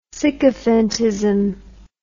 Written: sycophantism

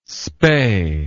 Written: spay

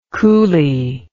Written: cooly

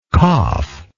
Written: caph

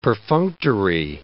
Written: perfunctory

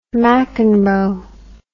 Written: mcenroe

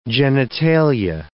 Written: genitalia

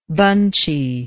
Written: bunchiness

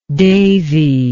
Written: devi

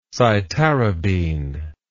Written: cytarabine

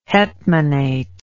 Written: hetmanate

